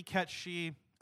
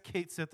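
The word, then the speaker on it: Cait Sith: It's supposed to be Ketchi. I guess Kate Sith is fine. Technically, that's the official pronunciation now.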